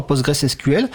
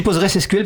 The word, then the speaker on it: PostgreSQL: ingés est-ce qu'il y a des formations à PostgreSQL à Postgres putain alors je dis PostgreSQL parce que ça s'écrit SQL à la fin de Postgres on peut dire les deux voilà on peut dire les